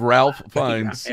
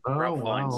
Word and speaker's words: Ralph Fiennes: Is it Ralph? Have I been mispronouncing? – You have, Ralph Fiennes. – Yeah. Ralph Fiennes.